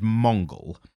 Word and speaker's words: Mongul: So his name is Mongul. It's M-O-N-G-U-L. – Yeah, no, it can only be pronounced that way, unfortunately.